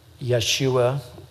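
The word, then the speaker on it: Yeshua (יֵשׁוּעַ): Remember again, Joshua is Yeshua, the Hebrew pronunciation, which of course is Jesus.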